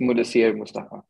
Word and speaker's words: Mudassir Mustafa: So it's Mudassir Mustafa. So that's how I pronounce my name.